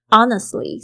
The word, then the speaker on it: honest: 呃，I want to honestly，honestly 这个字大家稍微注意一下，这个 honest 它的拼法是 H O N E S T，这个 H 没有发音哦，honest，honest 这边 honestly 对不对？特别注意一下 T L Y 这个。发音呢，有时候 t 呢，通常会发不太出来。 Honestly say 就是说呢，说这样子的话。